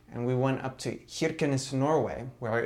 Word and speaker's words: Kirkenes: Kirkenes. I like how you say that. That's very Kirkenes. – Kirkenes. – Yeah. Yeah, that's how they say it, supposedly.